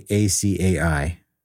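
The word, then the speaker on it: açaí: I got an, how do you say ACAI? – Acai.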